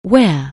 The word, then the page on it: wear: anglais